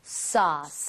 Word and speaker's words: sauce: AU나 AW 스펠링이 나오면 O보다 조금만 입 모양을 벌려준다. 이 느낌. – 아. – 그래서 소스보다는요, 소스. 소스. 살짝만 벌리시면 되거든요. – 소스. – 한번 더. – sauce.